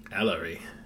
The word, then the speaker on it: Ellery: Ellery. – Yes. – Rhymes with celery.